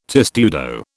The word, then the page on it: testudo: British English